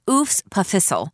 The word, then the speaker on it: EWSPFHSL: as if the EWSPFHSL, pronounced Oofs Puffissel, was some kind of parasite living in the belly of this great center of learning.